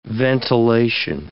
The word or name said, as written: ventilation